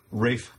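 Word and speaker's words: Ralph: With, how I can remember how you actually pronounce his name. – Rafe Fiennes.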